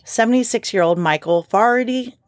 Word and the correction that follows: Faherty: I don't think that's how you say it. Yeah, okay. It's F-A-H-E-R-T-Y. Faherty. Faherty.